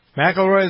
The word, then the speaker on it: McIlroy: McIlroy, is how you pronounce his name? – Yeah.